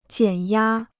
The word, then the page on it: 减压: jiǎn yā